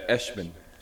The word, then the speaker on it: Eshman: Yeah, Eshman. Eshman. Rhymes with freshman.